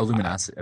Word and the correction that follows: Lumino City: I think it's pronounced Lumino City, though, because it's like...